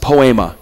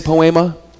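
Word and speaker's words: poema (ποίημα): The Greek word that stands behind it is pronounced poema. Can you say poema? Do you hear an English word tucked in there? Poem. You are God's poem.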